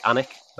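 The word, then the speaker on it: Alnwick: I mean, there's places like Annick that's often mispronounced, that people are aware of now because of the likes of Harry Potter being based out of Annick Castle.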